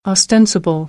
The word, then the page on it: ostensible: American English